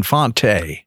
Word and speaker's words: Fante: Did I say it right? – Stephen, Fonte. – Stephen Fonte. Fonte. – Yeah, a lot of people say Fontaine.